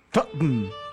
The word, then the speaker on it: Tottenham: decimos, en vez de decir Tottenham, decimos Tottenham. – Tottenham. Pues. Tottenham. Son dos sílabas.